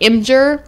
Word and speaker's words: Imgur: You pronounce it like, and someone yelled at me for pronouncing it Imgur, but I'm not hip I thought this. it was Imgur. – Yeah, it, it, spelled like Imgur.